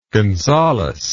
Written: gonzales

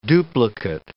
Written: duplicate

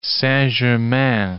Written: Saint-Germain

